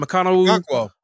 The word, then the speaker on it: Okonkwo: Okonkwo.